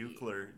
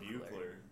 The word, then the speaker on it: Buechler: Buechler. Just say Buechler. Like, maybe it's pronounced like that just because there's this author, Benjamin Buechler. – Yeah. – Buechler.